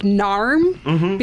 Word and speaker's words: Norm: Be- because the way that they say Norm Peterson's name, they pronounce it like Narm because of the Boston accent.